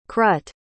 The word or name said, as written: crut